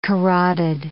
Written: carotid

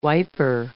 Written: wiper

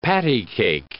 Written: patty-cake

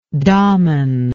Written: dhaman

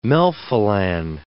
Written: melphalan

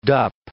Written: dup

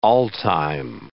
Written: all-time

